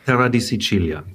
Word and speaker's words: Terra di Sicilia: Ja, ich hatte die Ehre, dich schon kennenzulernen und habe vor kurzem dein neues Buch gelesen, Terra di Sicilia. Richtig ausgesprochen, oder? – Terra di Sicilia, genau.